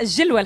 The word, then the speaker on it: الجلوة: أنا حضرت عليها الجلوة الحمامية تحديدا، هاو كيفاش تنطق بكسر الجيم.